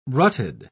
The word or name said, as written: rutted